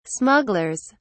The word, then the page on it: smugglers: British English